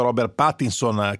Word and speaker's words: Robert Pattinson: E quanto invece alla fine della saga, la conclusione di questo percorso che ha visto Robert Pattinson crescere come uomo, da ragazzino a insomma uomo maturo, anche come attore, cercare anche altre strade, l'attore appunto si pronuncia così.